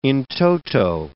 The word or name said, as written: in toto